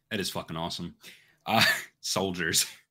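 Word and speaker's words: Souldiers: And finally, Soldiers, but it's spelled like soul, like S-O-U-L, Soldiers.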